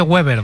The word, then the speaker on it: Weber: Y está Manfred Weber, que se dice Weber, no se dice Weber, ¿vale? Sí, se dice Manfred Weber.